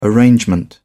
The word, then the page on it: arrangement: British English